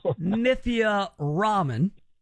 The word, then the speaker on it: Nithya Raman: It's pronounced Nithya Raman, but we'll take whatever. I mean, look, if you Nithya Raymond, that's fine too. R-A-M-A-N, man, Raymond.